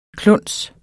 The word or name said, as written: kluns